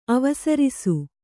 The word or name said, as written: ಅವಸರಿಸು